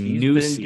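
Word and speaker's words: Neuse: Most people don't even know how to pronounce his name. – They say Noisy. Good.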